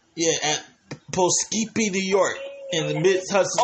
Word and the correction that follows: Poughkeepsie: Oh, you mean Poughkeepsie is pronounced.